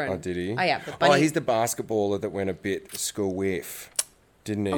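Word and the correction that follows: skew-whiff: How do you say squiff? – Skew whiff. – Skew whiff. – Yeah. – What do I say? School whiff. Oh, God. I've always said school whiff.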